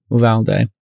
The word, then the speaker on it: Uvalde: Uvalde, I believe is how it's pronounced. – Uvalde.